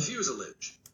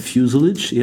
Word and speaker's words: fuselage: Amerikanische Aussprache. – Fuselage. Hört man das? – Ja, das hört man noch, oder? – Fuselage. Also Burnt Fuselage, ihr Amis? – Oder, wenn man sich entsprechend britischer fühlt... – Fuselage.